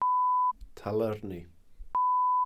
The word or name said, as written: talyrni